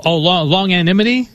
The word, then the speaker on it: longanimity: Oh, longanimity? – Longanimity, okay.